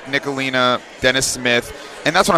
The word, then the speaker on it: Ntilikina: Ntilikina. – Ntilikina.